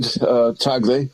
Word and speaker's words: Tagle: Tagle? – Yeah, Jesuit educated, Tagle.